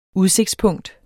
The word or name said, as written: udsigtspunkt